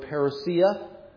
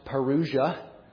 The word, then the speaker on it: parousia: Sometimes you hear the word parousia. Sometimes you have it erroneously pronounced parousia. But it is parousia, which means coming or presence even.